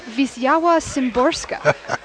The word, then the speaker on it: Wisława Szymborska: And the wonderful poet Wisława Szymborska. – Well done, well pronounced.